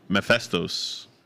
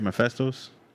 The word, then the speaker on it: Mephisto: Mephistos? – Mephistos. – No, it sounds weird. That doesn't sound like his name. – Now I'm thinking of Mentos, Freshmaker. – Mephisto, just no S, right?